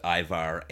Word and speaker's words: Ajvar: And for people wanting to look up what that is, if they're not familiar with it, it's spelled A-J-V-A-R, but pronounced Ivar.